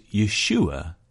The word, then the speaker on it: Yeshua: Jesus' name, pronounced Yeshua in Hebrew, meant Yahweh saves.